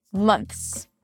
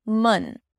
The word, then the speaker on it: months: To say this word, we begin with the M sound and then the as in cup vowel sound. Mun. Months. Now, it sounds like a stressed schwa. You relax your jaw just a bit. The lips are relaxed and the tongue is centralized.